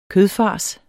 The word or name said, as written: kødfars